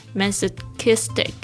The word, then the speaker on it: masochistic: To think otherwise is masochistic. 好的，这边我们来看一下，就是要记忆的单词。第一个就是 poisonous。 P O I S O N O U S，poisonous，有毒的、恶毒的、讨厌的。 Number two，masochistic，特别注意一下这几个发音哦，特别是 C H 这个声音是 k 的声音。 masochistic 就是指受虐狂、自讨苦吃的人，就是 masochistic。